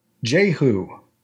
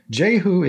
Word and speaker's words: Jehu: And this word is pronounced J-Who. – Okay. – J-Who is spelled J-E-H-U, and it is a noun.